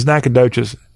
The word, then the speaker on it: Nacogdoches: I know how to pronounce it in Texas. It's Nacogdoches.